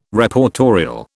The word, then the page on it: Reportorial: British English